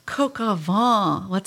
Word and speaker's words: coq au vin: Well, we had coq au vin.